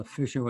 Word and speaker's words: Fischer: It's spelled, the name Fisher spelled with a C in it, which is a German spelling. Mm-hmm. Fisher with a German, F-I-S-C-H-E-R, it means a German fisherman.